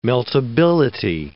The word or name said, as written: meltability